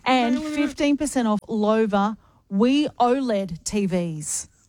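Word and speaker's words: Loewe: You can upgrade your home entertainment with the premium Lowy TVs at Harvey Norman. – Lova. – Oh, is it Lova? – See, at the top, how it says Lova? – Oh, yes. – Almost rhymes with sofa? – Yep.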